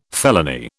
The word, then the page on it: felony: British English